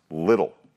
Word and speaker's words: Liddell: I'm speaking of the famous Olympian and then missionary Eric Liddell, who, to the best of my knowledge, has a last name that's pronounced Little.